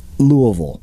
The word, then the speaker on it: Louisville: Side note, for non-Kentucky-based listeners, that is the proper pronunciation. Try it now. Louisville.